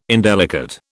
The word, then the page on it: indelicate: British English